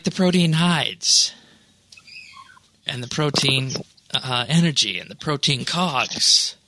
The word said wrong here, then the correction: protean: Is that not the way it's supposed to be pronounced? Protein? – I've always read it as protean.